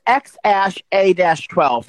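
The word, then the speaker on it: X Æ A-12: No, it's X and the AE is like it's another form of language and it's pronounced ash. So it's XASHA-12.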